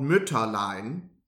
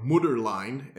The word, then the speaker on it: Mütterlein: The original version of this was, in fact, in German, called Mütterlein, or Mutterlein, as he pronounces it.